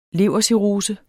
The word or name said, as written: levercirrose